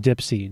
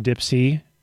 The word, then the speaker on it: DIYPSY: I'm so happy, that's actually pronounced Dipsy, DIYPSY equals Dipsy.